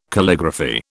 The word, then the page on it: Calligraphy: British English